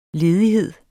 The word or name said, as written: ledighed